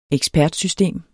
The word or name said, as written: ekspertsystem